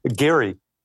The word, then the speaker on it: Gerry: uh, Irish and Scottish say Gary and it's spelled G E R R Y huh, okay.